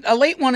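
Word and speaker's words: Ale-8-One: It doesn't mean a late one is their catchphrase. You know, that's how you pronounce it. That's why it's in quotes.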